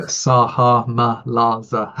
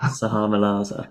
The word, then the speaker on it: Sahamalaza: Absolutely, and it's great that you've mentioned certainly in Salamahaz. Did I say that right? Salah Yeah, Sahamalaza. – Sahamalaza.